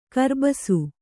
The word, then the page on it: ಕರ್ಬಸು: karbasu